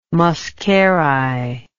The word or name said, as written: muscari